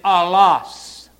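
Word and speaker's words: allos (ἄλλος): It is allos. I'll spell it in English. A-L-L-O-S.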